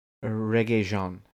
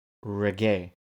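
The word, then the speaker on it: Regé-Jean: Yeah, Reggae Jean. It's Reggae. That's how you pronounce his name.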